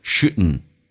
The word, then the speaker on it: shouldn't: shouldn't 在口语化当中 dn 组合经常 d 是不用发音，直接可以说成 shouldn't。同时呢 should 跟 have 它有一个弱化现象，把 h 音去掉， t 也去掉，用 n 跟 a 形成个连音，而且 a 可以弱化成 a，就变成 shouldn't have 那个 v 也可以不发，变成 shouldn't 也是可以，就等于 shouldn't have。